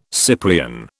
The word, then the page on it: Cyprian: British English